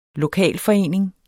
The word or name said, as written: lokalforening